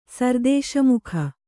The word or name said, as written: ಸರ್ದೇಶಮುಖ